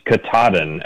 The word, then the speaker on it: Katahdin: And I'm just going to, off the top, say that the pronunciation is Katahdin, actually.